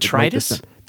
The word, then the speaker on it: detritus: And that's what creates the magic of microfiber is the surface areas, all the little hairs that pick up deritrus. Did I say that right? Someone corrected me last time and I had to go look it up. I think I got detritus. – Detritus. Thank you.